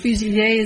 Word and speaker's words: fusilier: It's French. Fusilier is the correct pronunciation, and it means sharpshooter.